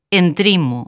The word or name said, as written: Entrimo